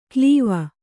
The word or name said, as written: ಕ್ಲೀವ